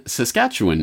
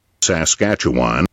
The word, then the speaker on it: Saskatchewan: He was born in Saskatchewan in 1947, or as the Americans insist on pronouncing it, Saskatchewan.